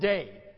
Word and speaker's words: dei: Now that word must in the Greek is a simple word, dei, phonetically D-E-I.